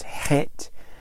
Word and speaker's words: Het: It's pronounced Het, spelled C-H-E-S.